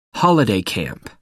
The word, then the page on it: holiday camp: American English